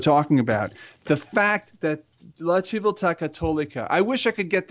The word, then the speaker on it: La Civiltà Cattolica: La Civiltà Cattolica.